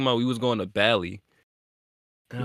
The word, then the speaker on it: Bali: It's spelled like Cali with a B. – It's Bali.